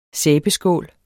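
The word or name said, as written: SÆBESKÅL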